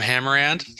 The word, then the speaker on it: Hammerand: I'm on LinkedIn at Danielle Kirsh, K-I-R-S-H. What about you, Jim? – I'm on LinkedIn at Jim Hammerand. The last name is spelled like the word hammer, A-N-D.